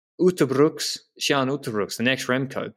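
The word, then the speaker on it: Uijtdebroeks: Kian Uttebroeks, that's how you, I think, properly pronounce it. Just wanted to throw that in there, because it's completely different to what you said.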